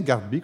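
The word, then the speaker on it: Garthby: C'est bien Gardby qu'on prononce, hein? tout à fait.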